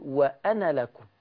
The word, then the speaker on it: وأنا: ألف أنا عند وصلها لا تنطق الألف، لا تنطق. أقول وأنا لكم وأنا لكم.